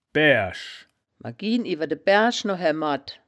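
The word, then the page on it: baeaerisch: wittlicher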